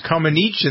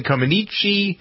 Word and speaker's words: Comaneci: It was Comaneci and then Comaneci. I think that debate has continued for the 36 years because you hear it still pronounced both ways.